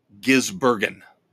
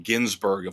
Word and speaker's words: Gisbergen: But Shane Van Gisbergen, Gisbergen, sorry, very hard name to pronounce. Gisbergen. I keep wanting to call him Ginsburg, of course, after the the late Supreme Court justice.